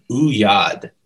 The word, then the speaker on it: oeillade: It's called, I want to say the pronunciation is Ouyad. – Ouyad. – And Ouyad, and it's spelled O-E-I-L-L-A-D-E.